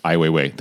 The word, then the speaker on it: Ai Weiwei: So you bring up um, the Chinese artist Ai Weiwei. Ai Weiwei.